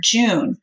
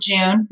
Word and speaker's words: Jun: and it's called Jun or June, J-U-N. We say Jun rhymes with fun, but you can call it June.